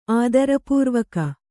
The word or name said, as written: ಆದರಪೂರ್ವಕ